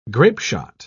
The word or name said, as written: grapeshot